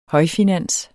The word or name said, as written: højfinans